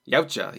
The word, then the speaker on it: Yautja: How do you say it? – Yautja, yes, that's what the Predators are called. – I believe that's how Ed says it,